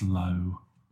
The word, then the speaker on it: Low: And uh, today we're going to be talking about David Bowie's Lau. – Great. And low. Abs- sorry? I think it's I think it's pronounced low. Low. Low. Okay. Like the noise a cow makes.